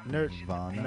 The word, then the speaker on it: Nirvana: Nirvana. – Nerve? Nirvana. Like nerve? a nerve, like nerve pain. My nerves hurt. I'm nervous. Nervous Nirvana. – Okay. Anna, like Anna.